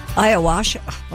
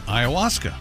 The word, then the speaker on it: ayahuasca: Ayahuasca. Well, now you've got me screwed up. Ayahuasca? – Nope, ayahuasca. – Ayahuasca, that's right. – Yes. – I have it written down that way, ayahuasca.